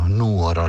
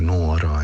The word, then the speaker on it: Nuoro: E poi ho, ho apprezzato il, il, fatto che eh, incredibilmente lo speaker sia stato ben eh, instradato, cioè eh, non ha sbagliato un accento, Nuoro l'ha chiamata Nuoro e non l'ha chiamata Nuoro.